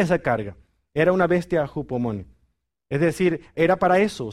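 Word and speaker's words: Hupomone (ὑπομονή): La palabra paciencia es una palabra griega que se pronuncia así. Hupomone, imagínense, es una palabra interesante.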